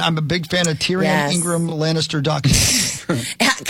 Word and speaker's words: Tyrion: I always think like Ty. I think Ty first, and then I say the name, because if I don't, I will say that. – Yep. – Tyrion.